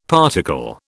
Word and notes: particle: British English

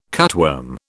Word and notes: cutworm: British English

